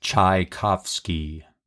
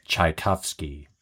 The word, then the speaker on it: Tchaikovsky: Tchaikovsky.